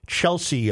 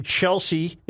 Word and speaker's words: Chelsea: He continually says Chelsea, and it is very aggravating. The name Chelsea is pronounced Chelsea, not Chelsea.